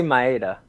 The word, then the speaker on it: Maeda: How do you say it? Is it Maeda? – I say Maeda.